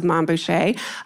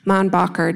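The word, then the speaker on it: Mainbocher: The social set flocked to the newly minted house of Man Boucher, a combination of his first and last name with, of course, the French pronunciation. Man Bacher doesn't have the same ring.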